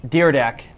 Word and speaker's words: Dyrdek: Some of the other people featured in the film are Barbara Corkin of Shark Tank, Rob Dyrdek, I can't ever say his name right, but Dyrdek, there we go,